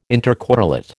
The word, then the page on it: intercorrelate: American English